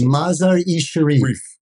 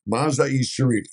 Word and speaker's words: Mazar-e-Sharif: In 70 and 71, he went to Mazar Sharif. I can't pronounce it. – Mazar-e-Sharif. – Mazar-e-Sharif. Thank you.